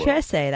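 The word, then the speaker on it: Trese: Trece, that's how you pronounce it? – Yes.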